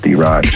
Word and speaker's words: D-Rock: I'm like, oh, D-Rock. Yeah, I know D-Rock. And I'm like, no, it's D-Rock.